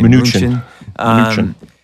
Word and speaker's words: Mnuchin: Mnuchin.